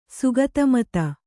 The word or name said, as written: ಶಾಕ್ತಮತ